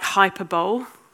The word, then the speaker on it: hyperbole: So I went into school saying stuff like hyperbole, hyperbole. I said ori instead of awry.